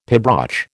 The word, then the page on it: pibroch: American English